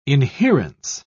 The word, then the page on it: inherence: British English